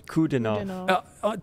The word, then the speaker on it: Kudinov: It's just a three-syllable word, man. Kudinov.